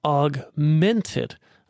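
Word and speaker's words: Augment3d: Another cool thing that was debuted actually at USITT at the theater show was ETC's Augmented. If you look at the name, it looks like Augment 3D, but they pronounce it as Augmented.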